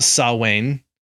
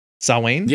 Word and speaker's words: Samhain: So pre-Burning Man and pre-Ja Rule, we have something that looks like it should be pronounced Samhain, but because it's Gaelic, it's pronounced Samhain. Samhain? – Yep. – Samhain.